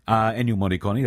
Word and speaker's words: Ennio Morricone: Ennio Morricone, or I should say, Ennio Morricone, that is the correct pronunciation, is, well, you'll know his music right away.